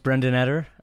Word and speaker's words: Brendan Eder: Well, it's a privilege to have you, and you've confirmed that we have indeed been saying your name incorrectly as Brendan Eder, but it is fantastic to have you here, Brendan.